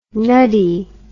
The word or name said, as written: nuddy